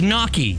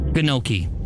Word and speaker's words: gnocchi: It's knocky. – What? – It's knocky. The G is silent. Like the word, like the bug gnat. The G is silent. Gnat. Gnocchi.